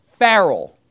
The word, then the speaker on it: Pharrell: Pharrell.